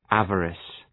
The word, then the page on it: avarice: ‘ævərıs